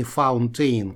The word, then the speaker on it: fountain: Вот это слово, интересно, носители произносят не как fountain, и уж, конечно же, никак не fountain, как оно написано. Они говорят так fountain, fountain.